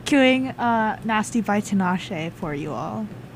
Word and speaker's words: Tinashe: Tinashe. – Tinashe.